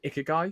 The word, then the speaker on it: ikigai: Ikigai, if I pronounce that correctly.